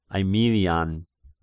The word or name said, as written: Aemilian